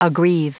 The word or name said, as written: aggrieve